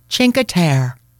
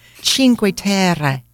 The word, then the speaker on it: Cinque Terre: This is how she pronounced it. Cinque Terre. – Okay. She's worse than me. – Right? Dio mio, she couldn't even pronounce it correctly. Cinque Terre. Cinque Terre is as crowded as Positano, Firenze, and Venezia.